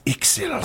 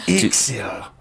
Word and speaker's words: Ixil: My name is Ixil Throxos. – I'm sorry? – Ixil Throxos. – Ixil Throxos. First name, 2 syllables. IXIL.